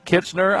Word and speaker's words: Kitchener: We have a tournament champion and the junior forward captain from Kitchener, Ontario.